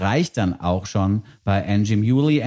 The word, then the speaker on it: Anjimile: Angie Muley, wer es nochmal nachgucken möchte und jetzt noch keinen Blick auf die Playlist werfen konnte, wird äh, hinten rausgeschrieben mit M-I-L-E, also A-N-J-I-M-I-L-E. Das Ganze wird Angie Muley ausgesprochen, was mit der Herkunft des Künstlers zu tun hat.